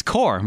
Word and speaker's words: Corps: And they went, it's Corps, Marine Corps. And I went, yeah, it is. It is. I was testing you. They went, you didn't know that's how it's pronounced? I went, no, there's a P. Why is there a P in there? It doesn't make any sense.